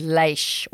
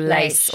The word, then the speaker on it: Laois: think she said it's written the way it's written and the way it's pronounced are very different. I thought she said leish or lace, or something yeah, like that.